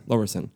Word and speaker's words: Lowerison: Lowerson. – Yeah. – But there's an I. – There is an I. Yeah. So it is Lowerison in how it's spelt, but right. The I is silent.